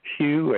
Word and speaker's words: Hugh: My name is Hugh, H-U-G-H. The last name, Trawlson, is spelled T like Tom, then T-R-A-U-L-S like Sam, E like Edward, N like Nancy.